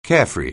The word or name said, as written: carefree